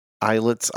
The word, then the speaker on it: islets: I-S-L-E-T-S. Islets. I think it's pronounced islets, like island.